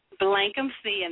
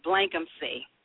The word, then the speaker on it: Blankumsee: Everybody messes it up. It's okay. Blankumsee.